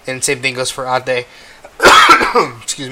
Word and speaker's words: ate: If you didn't know, my cousin, in the Filipino culture, we call older people of our generation that are female ate, which is spelled ATE. Which, you know, in English is pronounced eight, but in Filipino and Tagalog it is pronounced ate.